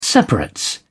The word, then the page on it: separates: British English